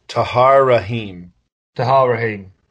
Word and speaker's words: Tahar Rahim: Tahar Rahim.